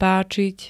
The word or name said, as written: páčiť